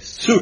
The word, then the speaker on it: Hsu: And the reason why, as explained in Ibarra, I keep calling it Ibarra because I don't know how to pronounce HSU. – Sue case.